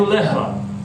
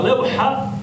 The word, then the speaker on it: levha: ljudi izgovaraju lehva, lehva. Ima u kući lehvu. Ako kaže lehva, ima u kući lehvu, konkretno rekao ima u kući strast, požudu. Jer je lehva strast, požuda, a levha je ploča.